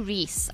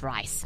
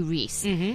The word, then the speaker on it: Rhys: It was the 78th Primetime Emmy Awards, and Matthew Rhys, I think is how you say his name, not Rice, Matthew Rhys, made history as the first actor to win two lead Emmy Awards in a single night.